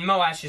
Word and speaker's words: Moash: Just Mosh sounds weird to me. – All right. – I mean, Moash is the correct pronunciation, so I guess we'll try to do that.